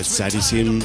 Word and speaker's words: Tzrizm: Charisim es súper complejo de pronunciar, pues se escribe como T-Z-R-I-Z-M.